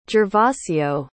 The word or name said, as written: gervasio